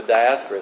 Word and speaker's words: diaspora: They were of what they referred to as the diaspora, the diaspora is how you pronounce that, the dispersion of the Jewish people.